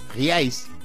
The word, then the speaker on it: reais: ¿Real es qué? Real. Y hay que pronunciarlo así. No es real como dice uno aquí. Reais. Reais.